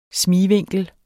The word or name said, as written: smigvinkel